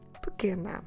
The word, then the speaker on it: pequena: P-E-Q-U-E-N-A. Pequena. So you've noticed that the U is silent, but also that first E is silent. We're stressing que, the second to last syllable in the word. So it means that the other E, the first one, we will drop it. So we just say pequena, pequena.